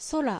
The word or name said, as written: Sora